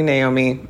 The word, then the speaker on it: Naomi: Um, so, anyways, I don't really correct people if they mispronounce my name because I was definitely told I was enough as a child, probably like every day of my life. But that being said, I mean, it's definitely Naomi and not Naomi.